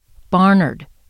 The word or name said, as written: Barnard